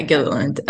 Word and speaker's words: Gilliland: How do you pronounce their last name? I always forget. Is it Gilliland? – Gilliland? Yeah, Gilliland. Rayleigh and Season from TikTok, and their acting is surprisingly very great.